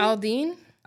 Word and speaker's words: Aldean: Aldean.